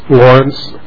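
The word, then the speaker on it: Lawrance: Mr. Chairman, members of the committee, my name is Lawrence Pansiera.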